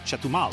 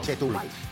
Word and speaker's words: Chetumal: Chetumal. – Chetumal.